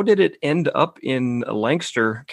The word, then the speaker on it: Lancaster: I know people out there say Lancaster. – Yeah. Good job, Lancaster.